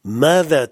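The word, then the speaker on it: Mada (ماذا): Et on va apprendre un nouvel interrogatif qui se prononce Mada. Mada, qui veut dire quoi, que.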